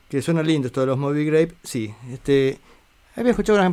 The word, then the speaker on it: Moby Grape: Eh, suena lindo Movie Grape. Claro, o Movie Grape o Movie Grape. Es Movie Grape. Tengo matar, perdón si estoy diciéndolo mal, porque yo lo, lo pronuncio como si fuera con doble O.